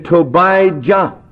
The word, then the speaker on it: Tobiah: Tobiah, or in its harsher Judean pronunciation, Tobijah.